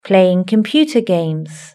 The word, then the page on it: playing computer games: British English